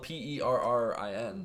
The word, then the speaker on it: Perrin: It's spelled Perrin. – But it also rhymes with Karen, which is, that's funny, a sec, if that's actually why they did it.